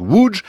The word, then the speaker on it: Łódź: Ça, c'est pour l'orthographe, mais ça se prononce Łódź, troisième ville du pays, 750 000 habitants.